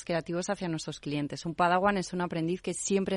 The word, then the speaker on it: Padawan: ¿Qué significa Padawan? Se pronuncia así, ¿verdad? – Así es, Padawan significa aprendiz.